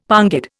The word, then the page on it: pongid: Indian